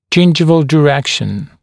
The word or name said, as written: gingival direction